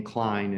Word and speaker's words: Cline: Yeah, incorporates our last name, Klein, in there. So yeah, Klein is spelled C-L-I-N-E.